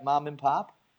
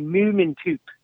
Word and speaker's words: mömandpöp: And if you were to pronounce it the way it would be with the umlauts, it would be mömandpöp.